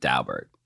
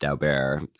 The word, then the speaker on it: Daubert: And judges were, in 1993, the Supreme Court decided this decision called Daubert. You know, and I, even though it looks like it should be pronounced Daubert, I've known people who know the Dauberts, and that's how they pronounce it.